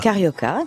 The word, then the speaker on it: Carioca: La Carioca, comment vous prononcez-vous? Carioca. – Carioca, comme ouais. Ça c'est bien.